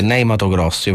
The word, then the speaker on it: Ney Matogrosso: che è Ney Matogrosso. Io faccio la pronuncia pronuncia così italiana. – Hai detto benissimo.